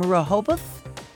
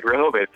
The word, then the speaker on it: Rehoboth: Okay is calling from Rehoboth, Delaware. And I think I must have not said that correctly. – No, you did. Rehoboth, Delaware.